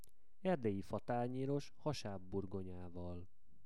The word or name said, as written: Erdelyi fatanyeros